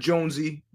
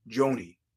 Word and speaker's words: Joney: How do you spell it? Johnny, J-O-N-N-Y, Boney, B-O-N-E-Y, Johnny Boney Joni, J-O-N-E-Y. Not Jonesy, Joni.